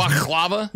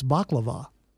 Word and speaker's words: baklava: Well, first of all, I'm trying to figure out, should I try to correct you on the pronunciation of something? – Because baklava? – Yeah, because it's baklava.